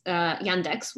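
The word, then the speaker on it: Yandex (Яндекс): And because they had Yandex, Yandex, which is how you say it in Russian, the Russian search engine.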